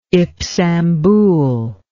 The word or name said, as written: Ipsambul